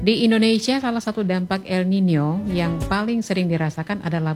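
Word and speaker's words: El Niño: Atau sering kita, kalau kita lihat tulisannya itu El Nino, tapi kalau bacanya El Nino.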